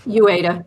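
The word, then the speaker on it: Uyeda: alongside of Commissioner Mark Ueda. Pardon me for perhaps butchering his Ueda.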